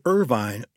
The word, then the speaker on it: Irvine: Well, let's start with that very famous place on the Kentucky River where Irvine, or Irvin as they pronounce it, now sits.